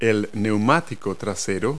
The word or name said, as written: rear tire